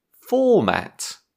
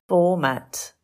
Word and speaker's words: format: Today's word is format. – Format. Stress on the first syllable.